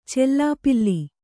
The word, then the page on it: ಚೆಲ್ಲಾಪಿಲ್ಲಿ: cellāpilli; Kannada